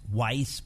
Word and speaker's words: Wisse: It's called Free as a Jew, Ruth Weiss, pronounced Weiss, but spelled W-I-S-S-E.